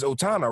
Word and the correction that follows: Otama: right? Otama? – Oh, so you just mean Otama.